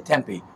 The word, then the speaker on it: Tempe: I notice you and everybody here says Tempe. Out east where I'm from, we all say Tempe, but you're supposed to put the pronunciation.